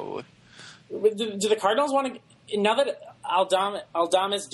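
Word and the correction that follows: Aledmys: Diaz, or however you pronounce his Aledmus. – Name. Aledmus.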